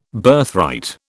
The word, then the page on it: Birthright: British English